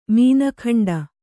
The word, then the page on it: ಮೀನಖಂಡ: mīna khañḍa; Kannada